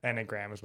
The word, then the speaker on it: Enneagram: I don't know why she just, oh yeah, she jumps into making a point about the, how do you say it, enneagram? – Enneagram is what I would say.